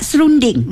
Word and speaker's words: serondeng: yang ketiga pula serunding. Ejaannya S-E-R-U-N-D-I-N-G. – Ding. Serunding. Sebenarnya S-E-R-O-N-D-E-N-G. Ding. Serunding. – Bukan D-I ya? – Ya. – D-E-N-G.